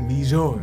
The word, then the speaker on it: Vijor: My name is like French. – I know. – It's two things, right? It's two syllables, right? – Vee-Jore.